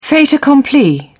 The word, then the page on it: fait accompli: British English